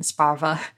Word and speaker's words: Sparver: He's got that kind of gruff, rough, get it done attitude, but also that like warm heart for Talia and for Sparver and Sparva, and as it's pronounced in the audiobook.